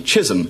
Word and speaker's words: Chisum: It stars John Wayne, and it's called Chisholm. Thank you. And it's spelt C-H-I-S-U-M.